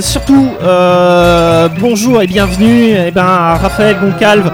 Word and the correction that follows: Goncalves: Et Goncalves, j'y arriverai quand c'est pas l'un, c'est l'autre.